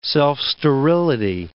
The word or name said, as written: self-sterility